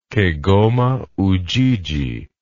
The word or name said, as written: kigoma ujiji